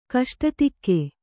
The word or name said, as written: ಕಷ್ಟತೆಗೆ